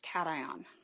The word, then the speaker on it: cation: Cation. You also could say cation, but it's usually pronounced cation.